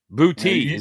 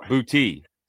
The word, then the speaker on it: Boutte: Boutte is how you say it. Boutte.